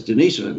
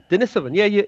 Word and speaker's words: Denisovan: See, Greg says Denisovan, and that's why I was pronouncing it that way. – So that's correct. – Yeah. – Was it? Denisovan, yeah, Greg got it right. You got it right.